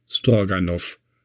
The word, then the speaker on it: Stroganov: Ähm, das wird in Russland immer mit off ausgesprochen. Stroganov. Da, und warum die Brandenburger jetzt also nicht Grabow und so sagen, das weiß eigentlich niemand so ganz genau.